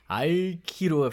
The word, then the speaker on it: Aikido: Aikido Fresno, that's how. – Yeah, yeah. That's the real way to say it. Emphasis on the I.